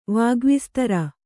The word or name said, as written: ವಾಗ್ವಿಸ್ತರ